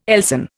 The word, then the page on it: Elsan: Indian